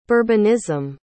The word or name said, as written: bourbonism